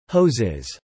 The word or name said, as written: hoses